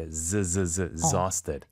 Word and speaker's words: exhausted: 여기 발음이 중요한 게 exhausted. – 이그저 아니고 exa가 되는군요.